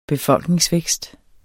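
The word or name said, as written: BEFOLKNINGSVÆKST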